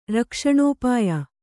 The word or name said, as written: ರಕ್ಷಣೋಪಾಯ